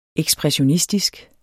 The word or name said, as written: ekspressionistisk